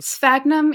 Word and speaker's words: Sphagnum: Sphagnum, yeah, it's like S-P-H, so it's like a sph kind of noise.